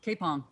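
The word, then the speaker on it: Kapyong: It's a basically a battle, but it's pronounced Kaepong, but it's spelled K-A-P-Y-O-N.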